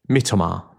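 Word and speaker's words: Mitoma: Mitomar.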